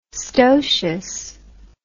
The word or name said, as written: stocious